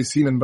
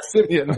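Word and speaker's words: Siemian: I want to say Seaman, but I'm not going to be saying it right. Yeah, Simeon. There it is.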